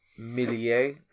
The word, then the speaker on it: Meslier: I mean, yes, Millier, I believe is how you actually pronounce the Leeds keeper. Great save, but I mean... – Meslier? – I think they pronounce it Millier.